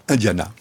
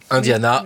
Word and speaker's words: Indiana: Vous le prononcez comment? – Indiana. – Indiana, donc on dira Indiana, Indiana dans le corps de l'histoire.